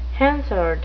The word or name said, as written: hansard